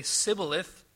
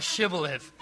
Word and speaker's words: shibboleth: Because they knew that the people of Ephraim could say sibboleth, but not shibboleth. They could not pronounce the SH sound.